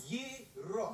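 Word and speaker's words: gyro: I'm telling you, Joe, it's pronounced hero. – Yeah, but in New York, we call them gyros. It's Greek. – Now, I got in a fight with Archimedes about this. He said it's... – Yiro.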